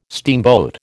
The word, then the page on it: steamboat: American English